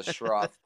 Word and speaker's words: Schroth: The Schroth seatbelt.